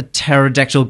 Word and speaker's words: pterodactyl: Why can't you hear a pterodactyl go to the toilet? – I don't know. – It's very cerebral because the P is silent.